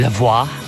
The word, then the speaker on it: La Voix: Is the actual pronunciation of that. I called her Lavoie, but it's actually Lavoie, as in voila.